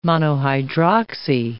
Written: monohydroxy

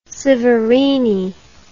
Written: Severini

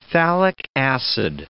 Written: phthalic acid

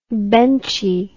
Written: benchy

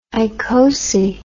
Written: icosi

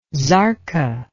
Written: zarqa

